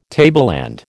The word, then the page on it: tableland: American English